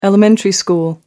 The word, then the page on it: elementary school: British English